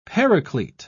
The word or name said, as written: paraclete